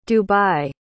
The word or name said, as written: dubai